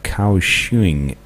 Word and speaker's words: Kaohsiung: And Taiwan International Airport and Kaohsiung International.